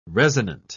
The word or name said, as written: resonant